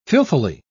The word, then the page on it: filthily: British English